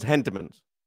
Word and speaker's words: Entenmann's: I've only, isn't there an H? – Yeah, Entenmann's. – Okay, it just sounded like you were saying an E with a silent H. – No, it's Entenmann's.